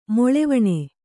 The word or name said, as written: ಮೊಳೆವಣೆ